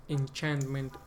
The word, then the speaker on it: enchantment: Sé que CH hay veces que se pronuncia como Q, pero enchantment es enchantment. Okay. La palabra se dice enchantment.